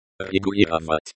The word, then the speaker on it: Регулировать: Регулировать. – Регулировать, to regulate. This is a verb. The stress is on the third syllable.